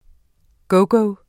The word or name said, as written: gogo